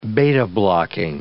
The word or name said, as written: beta-blocking